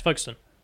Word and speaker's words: Folkestone: It's pronounced Folkestone, not... I just mispronounced it again. – How did you say it? – I pronounced it Folkestone, but it... Here, he's got it. – It's Folkestone.